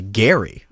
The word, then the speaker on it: Gerry: It's actually Gary. Not a lot of people know that. So it was former Massachusetts Governor Elbridge Gary. – Spelled that way, okay, but with a hard G, because of course back then there was no television to tell you one way or another how the name was actually pronounced.